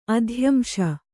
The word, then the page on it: ಅಧ್ಯಂಶ: adhyamśa